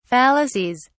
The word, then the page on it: fallacies: British English